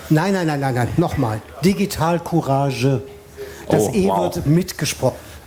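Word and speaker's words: Digitalcourage: You have to, or Digital Courage, as we say in German, you have to pronounce the last E. It's not a silent E.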